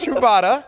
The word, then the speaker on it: Truvada: It's Truvada. I want to say it right. I'm like, come on, help me out, guys. Help me out with this, Truvada. Truvada, that happens to be one of the main drugs out there.